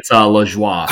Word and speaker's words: Lajoie: It's Lajoie.